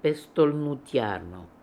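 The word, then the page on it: Pestolnootiarly: Russian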